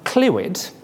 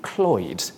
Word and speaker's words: Clwyd: Now, Clwyd is an accepted anglicised version, but it should really be Cloyd. Repeat after me, Cloyd.